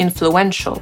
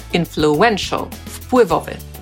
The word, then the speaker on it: influential: Możemy też od słowa influence utworzyć przymiotnik, influential. I wtedy już akcent nie jest na pierwszą sylabę, tylko na trzecią. Influential, wpływowy.